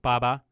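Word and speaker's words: Baba (八八): And we say, because in Mandarin, it's pronounced Baba, and it actually sounds very similar to bye-bye.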